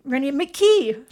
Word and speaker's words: Randy McKee: Randy McPhee. Randy McKee. I said it wrong.